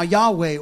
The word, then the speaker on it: Yahweh: We pronounce it now Yahweh or Jehovah. But really, these four letters in the Hebrew don't really have a pronunciation. They put a pronunciation to it later.